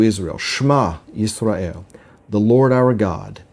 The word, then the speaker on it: Shema: and that's a word that's pronounced very similarly It's pronounced Shema, and it's spelled Shin Mem Ayin.